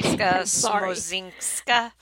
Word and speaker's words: Agnieszka Smoczynska: Agnieszka Smoczynska. – Yeah, Smoczynska. – They don't phonetically lay it out. – Darn. Yeah, Smoczynska.